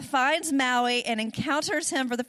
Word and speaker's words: Maui: I mean, how could it not be pronounced Maui, right? Okay, Maui.